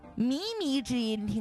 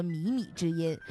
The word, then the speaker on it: 靡靡之音: 嗯、下一个成语呢叫做靡靡之音，听过吧？ – 靡靡之音听过。 – 错误的读音应该叫靡靡之音，靡靡之音。